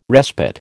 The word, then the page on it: respite: American English